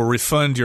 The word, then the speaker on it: refund: Usually the accent is on the second syllable when it's a verb, refund, although you will hear people say refund both as the noun and the verb form.